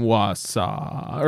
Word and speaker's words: Wausau: Wausau. – Wausau. – Like daddy. – Just like it. – Just like it. – A man is accused of hitting a power pole with his car in Wausau early Saturday morning.